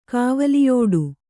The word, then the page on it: ಕಾವಲಿಯೋಡು: Kannada